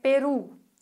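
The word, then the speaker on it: Perú: Wenn du weißt, es heißt Peru, dann kannst du dir überlegen, okay, das Wort endet auf Vokal. Aber wenn das Wort auf Vokal endet, dann müsste ich ja die vorletzte Silbe betonen. Aber das mache ich nicht, weil es klingt normalerweise anders. Also muss da ein Akzent drauf, weil sonst würde ich es anders betonen.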